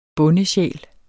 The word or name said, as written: bondesjæl